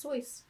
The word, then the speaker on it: Seuss: Actually pronounced Seuss. – Stop it. – Yeah, I know. Rhymes with rejoice.